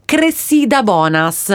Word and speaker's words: Cressida Bonas: Allora, lei si chiama, aspetta, lo devo leggere perché il nome è particolare, si chiama Cressida Bonas. – Ok. – Chiediamo conferma sulla pronuncia. – E lei? – In regia sorridono, però il pronuncia è corretta.